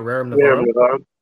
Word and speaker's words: Rerum Novarum: You talking about Rerum Novarum? – Thank you.